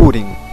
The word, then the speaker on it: Húrin: ¿Es Húrin? ¿Húrin? ¿Cómo se dice? – Húrin. – Húrin, ya, ok.